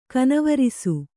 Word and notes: ಕನವರಸು: kanavarasu

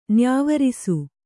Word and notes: ನ್ಯಾವರಿಸು: nyāvarisu

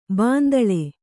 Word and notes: ಬಾಂದಳೆ: Kannada

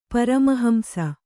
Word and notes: ಪರಮಹಂಸ: kannada

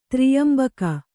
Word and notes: ತ್ರ್ಯಂಬಕ: Kannada

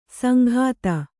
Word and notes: ಸಂಘಾತ: sanghāta

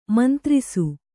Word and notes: ಮುಂದಿರಿಸು: mundirisu

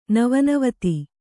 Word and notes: ನವೋನವತೆ: Kannada

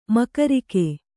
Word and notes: ಮೂಗರಿಕಿ: mūgariki

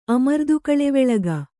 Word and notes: ಅಮರ್ದುಕಳೆವೆಳಗ: Kannada

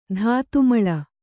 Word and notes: ಧಾತುಮಳ: kannada

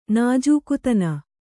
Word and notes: ನಾಜೂಕುತನ: nājūkutana

